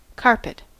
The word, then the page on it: carpet: [ˈkɑɹ.pɪt]; American English